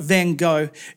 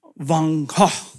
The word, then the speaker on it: Van Gogh: I believe the way you're meant to pronounce his name is not Van Gogh, it's Van Gogh.